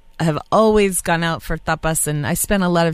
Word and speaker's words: tapas: But whenever I tell my friends, oh yeah, you know, if I don't pronounce it correctly, like in the Spanish way, people are like, you went to a topless bar? You know, you went to a topless. I was like, no, tapas, not topless.